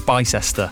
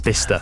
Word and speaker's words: Bicester: I used to think it was Bicester. It's Bicester.